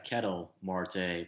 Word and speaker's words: Ketel Marte: But the other guys I wanted to bring up from the hot sheet, Ketel, is it pronounced Kettle or Ketel Marte? – The scouts I've talked to always pronounce it Ketel Marte, yeah.